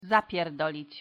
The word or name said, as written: zapierdolic